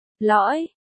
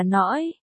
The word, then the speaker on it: lõi: Lõi, ông lão quát, L-O-I lõi. Không phải là nõi,